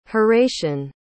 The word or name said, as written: horatian